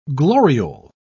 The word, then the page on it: gloriole: British English